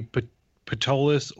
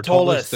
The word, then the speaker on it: Ptolus: I'm sure this would be an internet debate on the pronunciation, but it would be Ptolus or Ptolus.